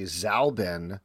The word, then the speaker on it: Zalbin: My last name is not Zombie. It's actually Zalbin. That's what the Z stands for.